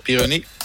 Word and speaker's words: Pironi: Dan hebben we nog Didier Pironi, zeg ik dat goed? – Ja, Pironi.